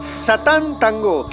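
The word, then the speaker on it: Sátántangó: Que es una película también medianamente reciente, de 1994, que se llama Satán Tango. Tango, pero con acento en la última O. Satán Tango.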